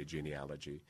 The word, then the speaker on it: genealogy: However, it's genealogy, genealogy. And so in my head, I pronounce it, and those rare times when I say it out loud, I say genealogy, so that I can remember that it's spelled with an A, not an O.